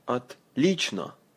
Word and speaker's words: Отлично: Отлично. Say, но. Лично. Отлично. Good.